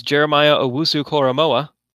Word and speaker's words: Jeremiah Owusu-Koramoah: One of them was Jeremiah Owusu-Koramoah. – It's an impressive pronunciation, I just wanted to say. I think it's right.